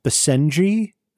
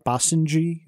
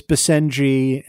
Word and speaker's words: Basenji: In TTTB 64, that was dog law, I mispronounced the name of the breed, which is apparently pronounced Basenji. I think I called it Basenji.